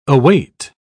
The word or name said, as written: await